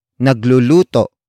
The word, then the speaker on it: nagluluto: For example, nagluluto palagi si Jack ng itlog. Nagluluto palagi si Jack ng itlog. Nagluluto palagi si Jack ng itlog. It means Jack always cooks an egg. Word for word, it's nagluluto palagi si Jack ng itlog.